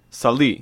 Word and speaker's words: Saleh: The rule was that, you know, Solawi, how do you pronounce his name? – I pronounce it, I have to say it all at once, otherwise it doesn't come out right. Ali Abdullah Saleh. – Saleh.